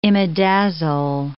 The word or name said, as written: imidazole